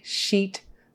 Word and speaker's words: sheet: Make sure you get your long E. E, sheet. You don't want to be making it a short I or it will come out as a swear word.